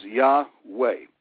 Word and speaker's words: Yahweh: And when YHWH is seen as it's written with the vowel points, we know the pronunciation is Yahweh. We would spell it Y-A-H-W-E-H.